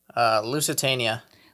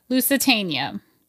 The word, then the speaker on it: Lusitania: Lusitania. – Lusitania.